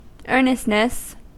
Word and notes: earnestness: American English

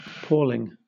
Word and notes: palling: British English

